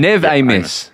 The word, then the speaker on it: Amos: Amos. That's another one. – Amos.